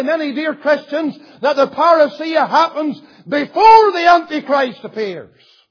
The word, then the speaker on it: parousia: It is the Greek word parousia.